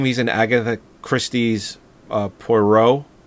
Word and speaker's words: Poirot: Yeah, Poirot. It's pronounced Poirot.